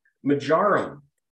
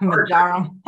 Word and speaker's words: marjoram: And then we also have a half teaspoon of marjoram. How do you say it? – Marjoram? – Not marjoram. – Marjoram. – You put the emphasis on the wrong syllable.